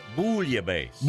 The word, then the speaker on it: bouillabaisse: Well, I mean, it's bouillabaisse. – Bouillabaisse. – Yeah. – Because it's like the French deal, right? – Yeah. – Okay. – Does that sound more French to you? – Yeah. Bouillabaisse.